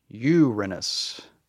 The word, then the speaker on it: Uranus: What you want to say is emphasis on the U, Uranus. Just like that. – Uranus.